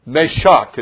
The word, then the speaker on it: Meshach: Meshach is how you pronounce Meshach, what we normally say is Meshach. We're just from South. It's a Meshach. I just live in a little old Meshach down the way.